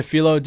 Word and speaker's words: phyllo: Anyway, but the whipped brie in that mango, was it a phyllo dough? Am I pronouncing that right? – Yeah, we did a phyllo dough, just crisped it with a little bit of granulated sugar, and gave a nice texture to the dish as well.